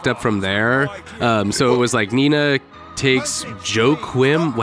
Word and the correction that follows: Joaquin: How the fuck do you say his name? – Joaquin. – Joaquin. Oh, okay, we're going with that. Okay.